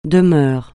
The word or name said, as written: demeure